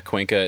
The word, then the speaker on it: Cuenca: I've seen some really beautiful photos of, is it Cuenca? Is that how you say it? – Yeah, I believe so. – And also what, like the Buttes, I think. – Okay, so that's Cuenca in Spain and Buttes in France.